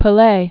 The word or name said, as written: Pelee